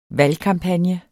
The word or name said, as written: valgkampagne